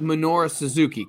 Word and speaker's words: Minoru Suzuki: Our good friend with the haircut, it's pronounced Minoru Suzuki, correct? – Yes.